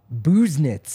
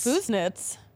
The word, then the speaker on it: Busenitz: Busenitz. – Busenitz. – Busenitz. Like, you know, you like you pick nits. Like you're being very particular and you're picking out something small.